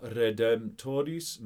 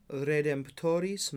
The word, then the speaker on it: Redemptoris: Let's just go through the Latin pronunciation. Alma Redemptoris Mater. – Alma Redemptoris Mater.